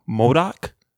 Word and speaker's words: MODOK: The third one is, now Joey, correct me if I'm saying this wrong, MODOK? – Yeah, that's right.